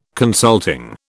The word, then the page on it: consulting: British English